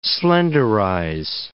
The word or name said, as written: slenderize